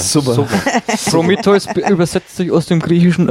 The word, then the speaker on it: Prometheus: Es sei denn, ihr wollt den deutschen Titel nennen, aber da wir hier englische Filme besprechen, der heißt ja nicht Prometheus auf Englisch, sondern Prometheus. So wird das ausgesprochen.